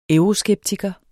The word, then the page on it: euroskeptiker: [ ˈœwʁoˌsgεbtigʌ ]; [ ˈεwʁoˌsgεbtigʌ ]